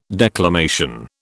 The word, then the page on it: declamation: British English